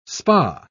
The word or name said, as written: spa